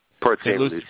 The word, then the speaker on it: Port St. Lucie: Lucie? St. Lucia? How do you say that? – Port St. Lucie.